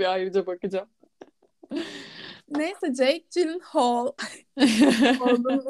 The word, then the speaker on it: Gyllenhaal: Bu şarkının da ilk orijinal versiyonu 10 dakikalıkmış zaten ve zaten en bomba kısmında Jake Gyllenhaal. Gyllenhaal.